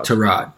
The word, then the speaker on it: Tyrod: There has been debate on how to pronounce his name this last week. – Tyrod. – I read from another source that his agent and father both addressed him as Tyrod to this particular source.